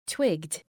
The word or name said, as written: twigged